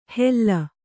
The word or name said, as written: ಹೆಲ್ಲ